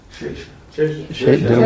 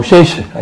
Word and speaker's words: Shesha: Shesha. – What did I say? Oh, Shesha. I thought you were saying I mispronounced it. I thought I said Shisha or something.